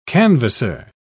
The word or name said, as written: canvasser